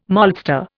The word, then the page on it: maltster: Indian